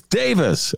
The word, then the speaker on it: Davies: And then you were the one who corrected me in the pronunciation of Ray Davies' name. It's Davis. Okay. even though it's spelled D-A-V-I-E-S, and he, of course, is the lead singer and a writer for the Kinks.